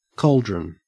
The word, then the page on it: cauldron: Australian English